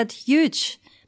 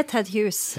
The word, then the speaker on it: Ted Hughes: Mange som syns det er vanskelig å uttale det navnet, for det er jo en H-U-G-H-E-S, så det er mange som tror at det er Ted Huge. Men det er Ted Hughes.